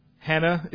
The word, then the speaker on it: Hannah: First Simeon, second Hannah. I don't know why it's spelled Anna. That will be something we'll just have to find out in heaven. Her name is Hannah. There is a rough breathing mark.